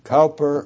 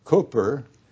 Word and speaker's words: Cowper: William, his name is spelled Cowper. I checked with an encyclopedia because he was well known, and either Cowper or Cooper are acceptable.